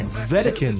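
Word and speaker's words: Wedekind: What? Vicknitz Spring Awakening at Gorilla Theater. – Vedekins. – Vedekins? Okay, well, you've got pronunciations here, and I'm just...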